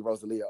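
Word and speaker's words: Rosalía: We still say her name wrong. It's definitely Rosalia. Oprah Rose told us that.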